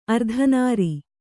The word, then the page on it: ಅರ್ಧನಾರಿ: ardhanāri